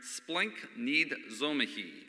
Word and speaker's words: splagchnizomai: It's spelled S-P-L-A-G-C-H-N-I-Z-O-M-A-I. And no, there's not going to be a quiz on this afterwards. But it's pronounced as best as I can understand here, splenknidzomahi, and it's a verb, and it means to be moved on the inward parts.